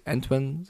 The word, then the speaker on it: Antoine: Und ich würde gerne einen Spieler noch ähm, äh, rausstellen, und zwar nicht nur Jonathan Ward, Antoine, ich glaube, so spricht man seinen Vornamen aus, Antoine, sagen die Amis, äh, Wesley, wie der jetzt spielt, nachdem der Andrew Hopkins fehlt als Receiver, der macht die Big Plays, fängt zwei Touchdown-Bälle.